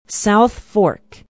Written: south fork

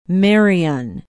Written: Marion

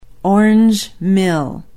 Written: orange mill

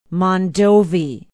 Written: Mondovi

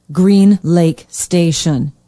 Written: green lake station

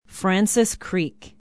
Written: francis creek

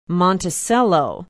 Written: monticello

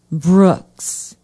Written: brooks

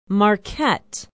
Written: marquette

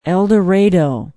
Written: eldorado

